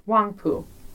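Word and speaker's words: Huangpu: Wangpu.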